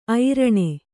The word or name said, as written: ಐರಣಿ